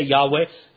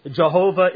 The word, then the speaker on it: Yahweh: Actually, it's more biblically correct, we would say, to say Yahweh. Jehovah is actually an old and somewhat incorrect pronunciation, but we won't quibble about that.